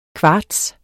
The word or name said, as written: kvarts